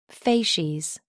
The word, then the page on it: facies: American English